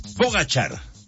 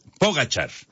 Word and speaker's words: Pogačar: Es Pogačar. Ya nos decía Memo García y ya lo estábamos checando también acá en diferentes páginas. Pogačar, la pronunciación correcta.